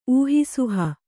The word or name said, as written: ಊಹಿಸುಹ